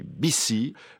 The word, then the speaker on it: Bizi: Jean-Noël, membre d'un drôle de beau mouvement, BICI, qui s'écrit B-I-Z-I, mais BICI.